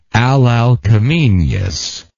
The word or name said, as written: Alalcomenean